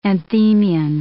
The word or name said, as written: anthemion